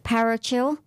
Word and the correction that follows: parochial: is that right? Parachal? P-A-R-O-C-H-I-A-L? – Oh, parochial. – Parochial, there you go.